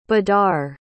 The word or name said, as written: badar